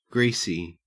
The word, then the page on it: greasy: Australian English